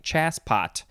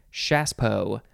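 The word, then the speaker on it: Chassepot: It's spelled C-H-A-S-S-E-P-O-T. C-H-A-S-S-E-P-O-T. It looks like chasspot, but you say Chaspo.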